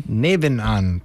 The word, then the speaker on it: Nebenan: I'm actually going to San Francisco for 3 weeks, and then I come back and I'm playing a show at Café Nebanon. I don't know how to pronounce it. – Nebenan? – Nebenan, thank you. It's my German.